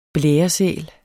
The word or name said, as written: blæresæl